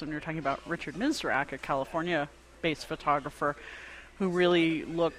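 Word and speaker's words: Misrach: And yet there were other people who ended up doing some, for example, Richard Misrock, is that how you say his name? – Yes.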